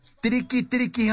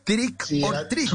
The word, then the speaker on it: Trick or treat: bueno, les, quiero plantear el, el, el, el tema de ir a pedir dulces diciendo triqui triqui Halloween, que es una cosa mal traducida, ¿no? Eso es eh, mal pronunciado incluso. Eso, eso es otra cosa, ¿no? Trick or treat. Trick or treat, sí.